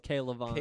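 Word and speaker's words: K'Lavon: Klavon. – I think it's Klavon.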